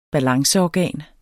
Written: balanceorgan

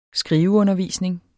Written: skriveundervisning

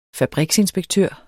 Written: fabriksinspektør

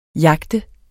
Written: jagte

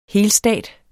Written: helstat

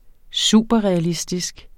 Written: superrealistisk

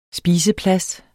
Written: spiseplads

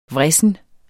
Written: vrissen

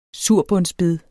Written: surbundsbed

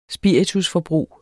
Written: spiritusforbrug

